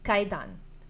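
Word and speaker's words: kaidan (怪談): It is pronounced kaidan.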